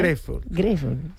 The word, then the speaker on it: Grateful: Grateful és com està ben pronunciat? – Grateful. Sí.